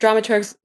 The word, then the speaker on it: dramaturg: I learned a new term when I was preparing to speak to both of you, and that is dramaturge. What exactly is dramaturge? And also, did I pronounce it correctly? – Dramaturg. And yes, who practices dramaturgy.